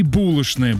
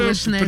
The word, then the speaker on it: булочная: В 19 веке, по-моему, как говорить «дождь» и «булочная» было булочная, признаком невероятного аристократизма.